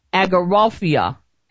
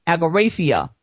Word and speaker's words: agoraphobia: I'm going, I, am going to pronounce this correctly, agoraphobia, agoraphobia. Now,